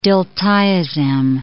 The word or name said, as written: diltiazem